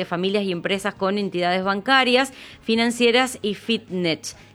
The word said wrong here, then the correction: fintech: ¿Se dice así? Sí, Fintech. Ahí Fintech. – está, me trabé.